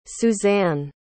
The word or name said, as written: Susane